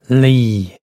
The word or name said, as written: laoi